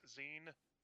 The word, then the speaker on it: zine: One or two comic shops near me that actually sold them, it was mostly a mix zine. And yes, I actually called it Zine, Z-I-N-E, because that's how it's pronounced.